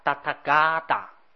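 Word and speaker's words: tathāgata: 其实这个达萨阿杰是我们现在用波波波这么念，在中国的古汉语里面念成叫达塔嘎达。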